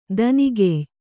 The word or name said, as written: ದನಗಾಯಿ